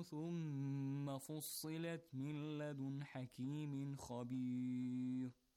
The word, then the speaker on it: خبير: When Ra is preceded, Ra, when it's preceded by kind of E sound, pronounce it lightly. مِن لَدُن حَكِيمٍ خَبِيرٍ. Not خَبِير. No. خَبِير. Lightly. No تَفْخِيم. Got it? It's as if it's carrying a كسر with it. Ri.